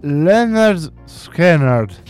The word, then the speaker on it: Lynyrd Skynyrd: I Lynyrd Skynyrd.